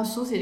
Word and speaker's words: sushi: 我说你知道 sushi 这个词儿是日本词吗？就是原来的发音就是 sushi。